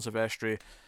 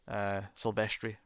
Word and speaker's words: Silvestri: Cool design of the Predator, cool music from Alan Silvestri. Silvestri, rather, say it properly.